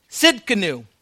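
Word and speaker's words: Tzidkenu: Jehovah, I can't even pronounce this, Tzidkainu. Tzidkainu, that's how you pronounce it. The T is silent.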